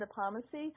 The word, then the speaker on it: Nepomucene: St. John Nepomucene, and I hope I'm saying that right. It's a beautiful church. – And if you're not saying that right, you make a better fist of it than I would. Go ahead. – I'm pretty sure that's correct. St. John Nepomucene.